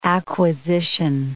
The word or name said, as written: acquisition